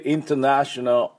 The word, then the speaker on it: International: でまずこの International、これ私どうしてもこれ International って言えなくてですね、アメリカ発音ですので、この Int のとかどうしても弱まって International って言ってしまいます。